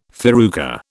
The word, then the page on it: farruca: British English